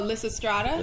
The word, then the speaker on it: Lysistrata: Oh, Lysistrata? – Lysistrata. – You said that so perfectly. She's had practice.